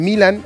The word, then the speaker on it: Milan: Alexis Vega de Toluca, Armando La Hormiga González de Chivas, Guillermo Martínez de Pumas, Santiago Jiménez de Milán, exactamente, de Italia, del Milan, o Milán, como lo quieran pronunciar, Raúl Jiménez del Fulham, de Inglaterra.